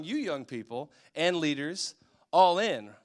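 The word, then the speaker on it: Ollin: So there's this Aztec word, since I'm very fluent in Aztecian, and the word is all in. It's O L L I N, not A L L I N, but it's O L L I N. It's pronounced all in.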